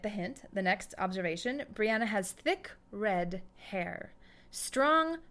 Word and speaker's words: Brianna: Claire pronounces it Brianna. – Brianna.